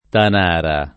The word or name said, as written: Tanara